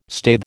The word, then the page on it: staithe: American English